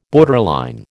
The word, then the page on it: borderline: American English